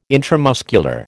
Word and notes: intramuscular: American English

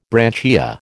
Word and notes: branchia: American English

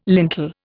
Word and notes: lintel: Indian

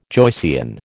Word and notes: Joycean: American English

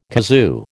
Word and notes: kazoo: American English

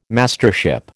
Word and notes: Mastership: American English

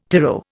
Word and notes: tyro: Indian

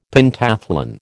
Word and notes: Pentathlon: American English

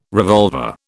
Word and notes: revolver: British English

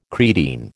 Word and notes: creatine: American English

